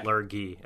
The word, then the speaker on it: lurgy: I always called it, or thought it was pronounced Lurgy, but so it's a hard G, then. – Yeah. – Lurgy, okay.